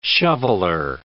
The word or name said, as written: shoveller